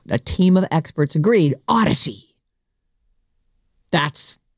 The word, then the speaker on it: Audacy: Hey, the Odyssey app is spelled A-U-D-A-C-Y. It's a made-up word, so it's not what you think. A-U-D-A-C-Y.